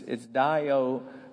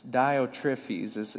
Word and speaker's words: Diotrephes: What was happening is there was this other guy named, it's very difficult to pronounce. It's Diotrephes. Diotrephes is how you pronounce it.